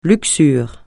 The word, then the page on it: luxure: francais